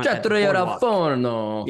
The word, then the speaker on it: Trattoria Al Forno: We booked, let me see if I can say this right, Trattoria Al Forno. The Italian restaurant. – Trattoria Al Forno. – Yeah, there you go.